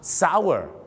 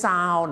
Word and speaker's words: sour: sour ก็ต้องออกเสียงว่า sour ไม่ใช่ sour หรือ sour นะฮะ